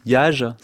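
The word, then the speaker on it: yagé: Les lettres du Yage, c'est comme ça qu'on prononce.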